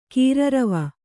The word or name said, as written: ಕೀರರವ